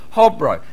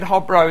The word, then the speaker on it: Hobro: Now, you don't say Hobro. If you said Hobro in Denmark, they wouldn't know what you're talking about, because it's actually pronounced Hobro. And you have to take something like a potato, put it in your mouth, and like that. And that's how the Danes speak.